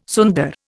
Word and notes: sunder: Indian

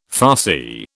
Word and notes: Farsi: British English

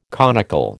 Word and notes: conical: American English

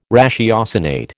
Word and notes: ratiocinate: American English

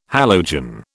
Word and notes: halogen: British English

